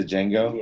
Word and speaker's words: Django: You must remember, the D is silent. – Oh, did I keep calling it to Django? – Yeah. – Sorry. – I think so. No, it's actually spelled R-A-N-G-O-D.